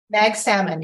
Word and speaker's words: Magsamen: Now, tell me how to, is it Magsamen? Is that the right way to pronounce it? – Magsamen, yes.